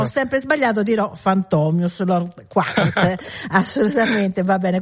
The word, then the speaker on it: Fantomius: Io lo pronuncio Fantomius, invece Fantomius qua. – Perché? Sì, allora la pronuncia è Fantomius, ecco.